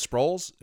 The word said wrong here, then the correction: Sprouse: is Sprouse. Yeah. He'll be the next speaker after this guy's done. – It's pronounced Sprouse.